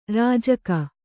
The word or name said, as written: ರಾಜಿಕೆ